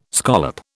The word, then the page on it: scallop: British English